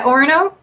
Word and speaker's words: Orono: Yeah, Orono.